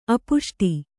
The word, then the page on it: ಅಪುಷ್ಟಿ: apuṣṭi